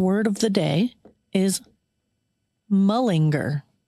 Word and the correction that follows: malinger: Is that how you say it? Malinger.